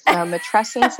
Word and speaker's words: matrescence: Matrescence like adolescence.